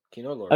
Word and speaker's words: Kino Lorber: Kino Lorber, how do you say that name of that company? – You said it right. Kino Lorber.